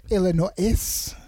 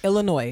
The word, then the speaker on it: Illinois: Illinois. – I've never heard that before. Like, Illinois. – That's terrible. No one should do that. No one should do that. Illinois is the correct pronunciation. That's it.